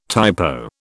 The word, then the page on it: typo: British English